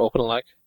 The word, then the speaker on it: OpenELEC: Am I saying that right, Dor? OpenELEC? – Yep. – Okay. – Yep, I believe so.